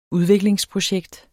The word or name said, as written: udviklingsprojekt